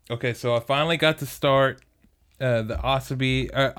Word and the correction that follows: Asobase: I think that's Asobase. – How you say it. – Asobase. – The first one is A-S-O-B-I-E.